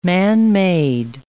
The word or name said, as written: man-made